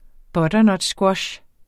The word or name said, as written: butternutsquash